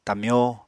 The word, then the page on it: tamyó: apatani